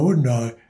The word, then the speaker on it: Hyundai: Ja, Hyundai. – Ah, Hyundai, so heißt es, wird es ausgesprochen. – Hyundai, ja.